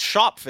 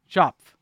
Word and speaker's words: Schopf: The Vancouver Whitecaps have won the rights to Alessandro Schopp. Schopp? I'm really having trouble with the name. I don't know why. Like, we have the proper pronunciation back. – We have it. – It's Schopf, isn't it? – Schopf.